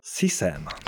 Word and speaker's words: CSAM: W tym epizodzie chcielibyśmy zacząć chyba od follow-upu do tematu głównego poprzedniego odcinka, czyli detekcji, jak się ostatnio dowiedziałem, to się wymawia CSAM. – CSAM.